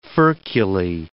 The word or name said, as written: furculae